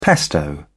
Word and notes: pesto: British English

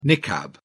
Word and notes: niqab: British English

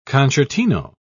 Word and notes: concertino: British English

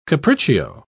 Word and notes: capriccio: British English

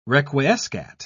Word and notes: requiescat: British English

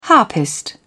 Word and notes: harpist: British English

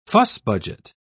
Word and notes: fuss-budget: British English

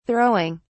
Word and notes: throwing: British English

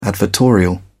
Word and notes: advertorial: British English

